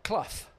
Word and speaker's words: Clough: How do you say it? Clough? – Yeah, Clough.